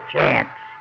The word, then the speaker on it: Cianci: His name was spelled C-I-A-N-C-I, but they pronounced it Chance.